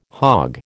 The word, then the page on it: hog: American English